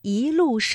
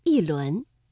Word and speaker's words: 一: 出自《浮生六记》的《闺房寄乐》，正确的声调应该是第一句的“ 一路双林”，我们的“ 一” 读二声“ 一路”；第二句“一轮明月”，这里的“ 一” 读四声“ 一轮”。